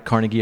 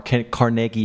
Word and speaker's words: Carnegie: So number five, the album is Dave Brubeck Live at Carnegie Hall, or Carnegie Hall, however you want to pronounce it.